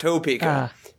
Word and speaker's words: Topeka: Sorry, everybody in Kansas says just... Topeka, like it's T-A-P-E-K-A, but it's spelled Topeka, but it's like Quebec or Quebec, it's Topeka.